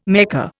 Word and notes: Mecca: Indian

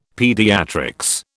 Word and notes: pediatrics: British English

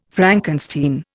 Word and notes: Frankenstein: Indian

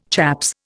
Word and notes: chaps: Indian

